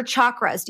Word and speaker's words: chakra: Moving into chakras, or chakras? Do you, are they pronounced chakra? – I say chakra, but I think the appropriate way is chakra.